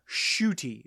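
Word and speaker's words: Ncuti: S-H-O-O-T-I is the pronunciation guide for it. – S-H-O-O-T-I. Shooty. Okay. So I still didn't pronounce the N. So it's not N Cutie.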